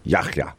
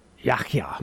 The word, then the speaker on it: Yahya: But when I looked at this a couple hours ago, I looked up the proper way to pronounce it in Arabic. And it's in Yahya. – Yahya.